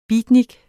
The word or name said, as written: beatnik